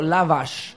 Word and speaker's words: lavash: Lavash.